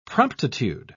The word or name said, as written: promptitude